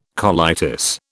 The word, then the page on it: colitis: British English